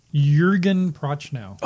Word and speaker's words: Jürgen Prochnow: I'm going to butcher his name. Jürgen Prochnow, the guy who plays Sutter Kane. – Jürgen Prochnow. – Oh, I was right.